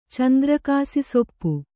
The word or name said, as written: ಚಂದ್ರಕಾಸಿಸೊಪ್ಪು